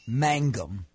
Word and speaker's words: Mangum: Jake Magnum from Mississippi State. – Close. – It's close enough. – Mangum. – Thank you. – It's not so much the pronunciation, it was the enunciation you got wrong. – Or the order of the letters.